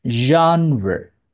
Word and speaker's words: genre: So that is the genre. Genre 就是类型的意思。我们在讲音乐，就是分很多类型啊。就是这个字，我们来念一下，它的发音是很特殊的。 Genre， Jumper. – 为什么发音那么怪？真的很怪，怎么会 R E 念 re 嘞？Originally is French，因为这个字本来是法文呐、啊。